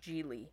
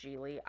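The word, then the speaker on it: Gigli: I think it's pronounced Jiggly. Spelled like giggly, but it's pronounced Jiggly. I don't know why.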